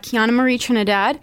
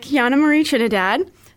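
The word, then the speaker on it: Keiyana Marie Trinidad: Yes, so you can find me on Instagram and TikTok at Kiana Marie Trinidad.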